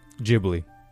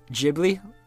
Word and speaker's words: Ghibli: Do you know how you pronounce that? – Ghibli. – Ghibli. – Yes. – Like giblets.